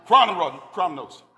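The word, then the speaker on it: Chronos (χρόνος): I had this pronounced right earlier. Chronos. Chronos, which denotes a fixed, a measured, an allocated season.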